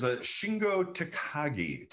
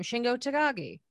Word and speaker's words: Shingo Takagi: The answer is Shingo Takagi. Tagaki. Shingo Tagaki. How is it? How do you spell it? – You said it right the first time. Shingo Takagi.